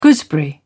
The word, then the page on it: gooseberry: British English